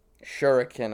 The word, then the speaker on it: shuriken: Shuriken, I would pronounce that.